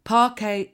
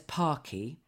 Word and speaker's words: Parquet: And she should never, ever work in the audio industry ever again because she pronounced Wiley as Willie. – No! – Pala as Parlor. Parquet as Parky.